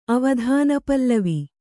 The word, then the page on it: ಅವಧಾನಪಲ್ಲವಿ: avadhānapallavi